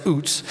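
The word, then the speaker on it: Uz: In fact, the first line is basically this: once upon a time, there once was a man from, and by the way, according to Wendell, it's pronounced Utz.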